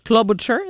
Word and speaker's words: Klobuchar: Est-ce qu'on dit Amy Klobuchar? Est-ce que c'est ma prononciation est bonne? – Oui, exactement.